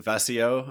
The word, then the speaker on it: WACEO: I know you have a company called, is it WACEO? Am I pronouncing that right? – Yeah.